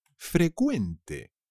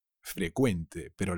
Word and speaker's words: frecuente: Se dice frecuente. Frecuente, pero la Q inglesa se convierte en una C, que se pronuncia como una K.